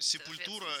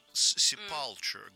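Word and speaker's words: Sepultura: Сепультура — это вот неправильное произношение. Точнее, это правильное произношение для нас и для, собственно, самой Сепультуры. А многие, значит, ребята, которые думают, что они знают больше всех, Sepulture говорят. Нет такой группы.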